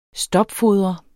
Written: STOPFODRES

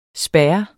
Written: SPAREN

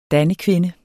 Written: dannekvinde

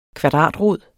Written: kvadratrod

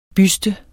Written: Bysten

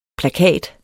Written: plakat

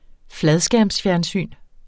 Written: fladskærmsfjernsyn

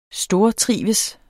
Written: stortrives

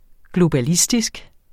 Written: globalistisk

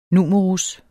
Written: numerus

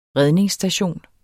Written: redningsstation

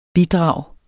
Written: bidrag

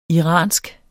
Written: iransk

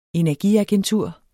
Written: energiagentur

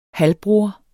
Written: halvbror

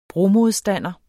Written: bromodstander

